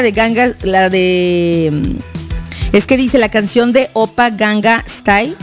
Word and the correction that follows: Gangnam Style: Gangnam Style.